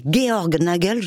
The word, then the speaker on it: Georg Nagel: Avec son collègue Georg Nagel, j'ai prononcé juste. – C'est parfait.